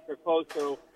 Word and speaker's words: Peposo: It's a dish called Peposo. It's an Italian dish. It's spelled P-E-T-O-S-O, Peposo. It's a Tuscan black beef stew.